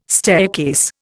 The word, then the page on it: staircase: Indian